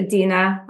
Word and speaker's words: Dina: It's uh, Dina. I'll Dina.